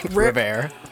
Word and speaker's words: Rivera: King actually spent time working with Riviera in Chicago. In Charlotte. Oh, my fault. Riviera. – Rivera. – I want to add the extra syllable in there so bad.